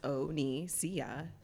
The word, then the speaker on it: Onisia: But like, I remember once I started a job, and like it was a room of 50 people, and I was like, my name's Onisia, and it's pronounced Onisia, and if you can't say that, you can have Oni for short.